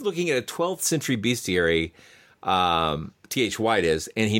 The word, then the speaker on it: bestiary: doing a translation of a 12th century, and I'm going to say the word, is it pronounced bestiary or bestiary? I've always been a little unclear on that. Like, it's primer and primer. – We usually say bestiary these days.